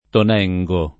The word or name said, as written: Tonengo